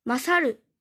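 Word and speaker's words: 勝る: 另一种训读读作 masaru。马萨鲁，这里的鲁是送假名，马萨鲁是胜过或比什么什么好的意思。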